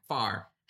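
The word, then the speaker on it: Phare: Far. – I only said it wrong on the first time. Literally in our pre-show, for those listening, like 30 seconds ago, it's far, not fair.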